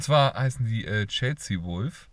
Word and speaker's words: Wolfe: Ähm, sind komisch geschrieben, also Chelsea, so wie Chelsea halt, und äh, Wolf, W-O-L-F-E. Deswegen muss ich gerade auch echt gucken, wie man die ausspricht. Ich hoffe, ich habe es jetzt richtig ausgesprochen. – Ja, hast du.